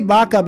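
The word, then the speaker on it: Vaka: I say Baca because in Spanish, Baca means cow, and you pronounce it with a B, even though it's spelled with a V.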